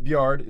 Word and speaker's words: Byard: They now have Kevin Byard. Is that is that pronounced? – Yeah.